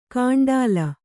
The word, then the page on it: ಕಾಂಡಾಲ: Kannada